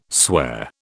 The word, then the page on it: swear: British English